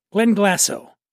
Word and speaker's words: Glenglassaugh: And she currently oversees the whiskey-making operations for 3 iconic Scotch brands, Benriach, Glenglassaugh, and Glendronach. Try pronouncing that 3 times fast when you've had a little whiskey. Benriach, Glenglassaugh, and Glendronach. That's how you say it.